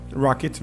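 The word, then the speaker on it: ROKiT: Inclusive, dentro do marketing ainda, a Williams tem a Telecom Rocket, né? Acho que é essa a pronúncia, né? – É a Rocket. – Rocket mesmo. Não sabia se era de uma outra língua, um outro idioma que não inglês.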